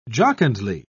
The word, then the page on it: jocundly: British English